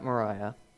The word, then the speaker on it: Moriah: And after 3 days, hold on to that, that's important, they see Mount Moriah. Not to be confused with Moria from Lord of the Rings, so I'm being very careful with my pronunciations.